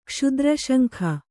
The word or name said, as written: ಕ್ಷುದ್ರಶಂಖ